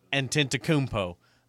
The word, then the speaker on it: Antetokounmpo: However you said it, that's not how you say it. – Antetokounmpo. – No. Antetokounmpo.